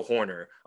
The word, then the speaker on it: Hoerner: So like nobody in baseball swings and misses less than Nico Hoerner among like at least 300 300 swings. He is the, am I saying that right? Yes. Nobody in baseball swings and misses less than Nico Hoerner.